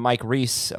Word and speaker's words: Reiss: One of the things from ESPN's article from Mike Reiss. Oh, did I say that right? Yep. Okay. Every time I always forget.